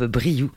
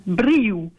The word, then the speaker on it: Briu: Et en revanche, le nom de notre groupe, c'est un nom gascon, donc qui vient vraiment d'ici, et on le prononce Briu, avec un ou à la place d'un U.